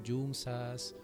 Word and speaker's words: yunza: Yunza se escribe con Y, ¿sí? Y, es con, y con Z, Yunza. Yunza. – Que nosotros no pronunciamos así la, la S como en España.